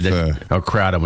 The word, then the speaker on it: kratom: Kratom, whenever you pronounce that. Yeah. – I think it's kratom. – Kratom, yeah.